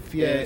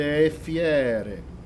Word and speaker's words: fiere: No, it's E is closed, fiere is open. – E fiere. – Right. So mostruose e fiere.